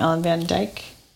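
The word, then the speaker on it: Ellen van Dijk: But my biggest shout out goes to, I think you pronounce her name, Ellen Van Dyke. – That sounds right.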